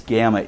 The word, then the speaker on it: gamma: It's gamma. It's the hard G sound. G as in go.